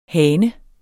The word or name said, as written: hane